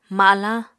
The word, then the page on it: malañ: apatani